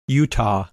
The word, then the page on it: Utah: English